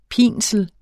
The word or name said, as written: pinsel